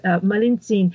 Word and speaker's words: Malintzin: So can we talk about this translator, Malintzin? I think that's how you say her name. – You got it. Right. Her name in Nahuatl and their language was Malintzin.